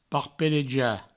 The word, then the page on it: parpelejar: parpeleja